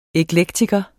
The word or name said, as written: eklektiker